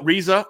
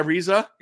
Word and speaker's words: Reza: Was told that I'm mispronouncing a lot of names wrong. That's what I found out today. Like, you know, Reza, our guy Reza. – Yeah. – It's Reza.